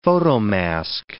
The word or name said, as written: photomask